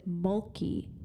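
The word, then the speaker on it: Mölkky: It's called, I think they pronounced it Molki, M-O-L-K, and then the letter U with the dots over it.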